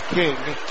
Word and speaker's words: Cing: First name is spelled C-I-N-G, but it's pronounced King.